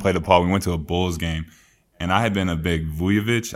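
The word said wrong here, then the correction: Vučević: I think that's how you say it. – Vucevic fan, like, ever since, like, you know, you just kind of think of, like, people that you compare to and play.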